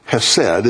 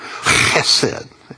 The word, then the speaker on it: Chesed: Chesed, it's the H sound, and it's the S sound, short E, S, short E, and then their D sound. You have to roll the H. See that breathing mark?